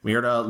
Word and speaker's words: Mirada: In 2010, he launched Miranda Studios, was longtime cinematographer, Guillermo Navarro, director Matthew Cullen, and executive producer Javier Jimenez. Mirada, did I say it wrong the first time? Maybe I did.